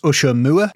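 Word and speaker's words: Ushaw Moor: He was a miner from Ushermore, which should be pronounced Ushermoor. – Mm-hmm. – But I pronounce it Ushermore because it sounds like something that your boss would say if you worked in a cinema.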